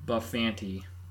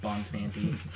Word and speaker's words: Bonfanti: Jim Bufanti on drums and backing vocals. – We're going with that. – Sorry, Jim. – Sorry, Jim. – Bonfanti.